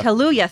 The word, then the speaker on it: Kaluuya: Kaluuya, thank you.